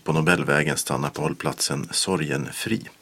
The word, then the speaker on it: Sorgenfri: Får man påpeka ett uttal? – Nej. – Det heter Sorgenfri. Jag lovar.